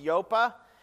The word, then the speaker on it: Joppa: And now send men to Joppa, that's how it's pronounced, Joppa, and bring one Simon who is called Peter.